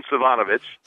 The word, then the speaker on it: Savanovic: I'm not going to ask how to spell it, but it's Sivjanovic. Am I close? – Yeah, it's Simon Savanovic. – Savanovic. – Yep, Savanovic. So that's what happens in the off season.